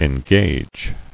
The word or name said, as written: Engager